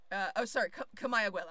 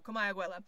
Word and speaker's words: Comayagüela: Comayagüela. I said that wrong. Comayagüela.